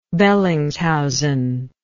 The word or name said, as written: Bellingshausen